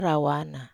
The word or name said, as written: rawana